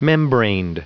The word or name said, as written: membraned